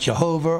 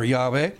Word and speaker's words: YHVH: And the Lord said, by the way, the word Lord there is the tetragrammaton, YHVH in the Hebrew, sometimes pronounced Jehovah or Yahweh.